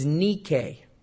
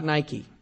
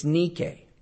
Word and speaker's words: Nike: Actually, the pronunciation of the Greek goddess Nike is Nike. It's not Nike. It's Nike.